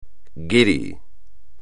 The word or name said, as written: guiri